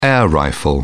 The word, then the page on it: air rifle: British English